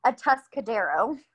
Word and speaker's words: Atascadero: Well, meanwhile, he started getting to know others at which by the way, I figured out how to pronounce it. It's Atascadero.